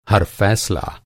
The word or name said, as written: हर